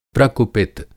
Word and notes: प्रकुपित: Hindi

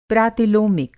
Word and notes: प्रातिलोमिक: Hindi

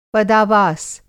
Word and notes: पदावास: Hindi

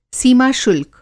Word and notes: सीमा-शुल्क: Hindi

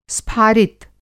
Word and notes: स्फारित: Hindi

